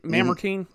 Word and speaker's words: Mamertine: So Paul, meanwhile, is imprisoned in Marmartine Prison. Is that how you pronounce that? Mamertine? Mamertine.